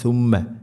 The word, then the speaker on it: ثم: സുമ്മ സുമ്മ സുമ്മ എന്നാവരുത്, ثم. അതുപോലെ മീമിന് ശ്രദ്ധുണ്ട്, മണിക്കണം.